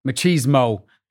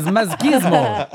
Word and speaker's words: machismo: Machismo. How do you say it in this country? – Machismo here. – Machismo, yeah. – Yeah. Are you using the Estonian pronunciation? – Yes, masgismo. Masgismo.